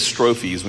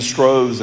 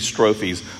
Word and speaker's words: strophes: And I did the Google thing, and it says strophes when you push the pronunciation thing. So if I'm saying it wrong, that's what Google told me to say. I've been saying stroves in other contexts, but it's supposed to be strophes.